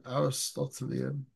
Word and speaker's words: Aristotelian: And this is really how do you say it? Aristotelian. Yeah, Aristotelian. Something like that.